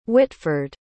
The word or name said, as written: hwitford